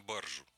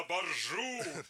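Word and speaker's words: баржа: Только здесь надо ударение поменять. Потому что любоваться надо не на баржу. – На баржу!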